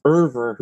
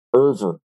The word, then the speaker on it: Euwe: His last name is spelled E-U-W-E. And I was always told that his last name is pronounced Erver.